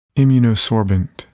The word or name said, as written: immunosorbent